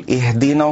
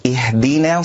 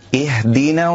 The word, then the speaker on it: اهدنا: البعض يقول اهدنا الصراط المستقيم، اهدنا نا نا، وهذا خطأ، والصواب أن يرقق النون والألف، اهدنا الصراط نا نا، اهدنا الصراط المستقيم.